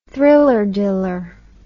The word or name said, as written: thriller-diller